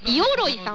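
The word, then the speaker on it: 五百蔵: 以前このコーナーで紹介されたイオロイさん、 500 の蔵と書いてイオロイさんという方も同じ部署にいるということで、うん、社内に珍しい名字の方が多い会社だということです。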